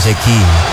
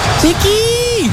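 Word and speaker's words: Zeki: Jackie Amdouni. 2-1 kini untuk Swiss. Eksekutor kedua mereka berhasil melaksanakan tugasnya dengan baik, mengecoh pergerakan dari Vargas. – Bacanya bukan Jackie. – Jackie. Gitu.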